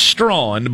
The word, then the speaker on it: Strachan: His name is apparently pronounced Strawn, but it's spelled Strachan.